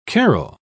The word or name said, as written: carrel